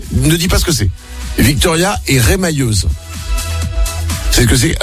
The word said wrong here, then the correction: remailleuse: On dit, ça se prononce pas comme ça? Remailleuse? – Remailleuse, oui. – Ah, remailleuse, pardon. – Ok, Eratum pour les mailles du coup, rien à voir.